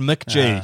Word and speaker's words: MCG: MCG, that's pronounced McGee, Sean. It's a little C.